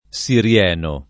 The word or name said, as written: sirieno